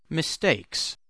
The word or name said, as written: mistakes